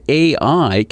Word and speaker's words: ai: Still, those with a romantic predilection note that AI can be pronounced as ai, the Japanese word for love.